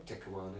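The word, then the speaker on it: Te Kanawa: Takawana, yeah.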